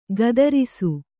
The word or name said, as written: ಕತ್ತರಿಸು